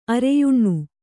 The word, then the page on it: ಅರೆಯುಣ್ಣು: areyuṇṇu